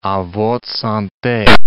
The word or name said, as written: a votre sante